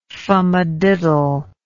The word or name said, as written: fumadiddle